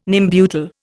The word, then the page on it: Nembutal: Indian